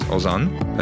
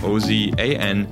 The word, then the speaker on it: Ozan: which is Ozan, that's spelled O Z A N to 345-345. So once again, that's my first name, Ozan, O-Z-A-N, to 345-345.